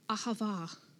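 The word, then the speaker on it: ahava (אהבה): The word is spelt A-H-A-B, but B at the end of a word in Hebrew is pronounced V. So we have the word ahav, or more commonly it has the A on the end, ahava, ahava. And it's actually pronounced by, you breathe in for the first bit and then breathe out, ahava.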